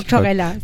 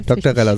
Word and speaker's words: Doktorella: Sandra und Kerstin Greta spielen einmal in der Band Doktorella. Ist das richtig ausgesprochen? – Ja. – Oder heißt es Doktorella? Das habe ich mich nämlich Doktorella. – Doktorella, so wie Barbarella quasi.